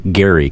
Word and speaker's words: Gerry: Look, actually, in his time, it was Eldridge Gerry because it's spelled G-E-R-R-Y.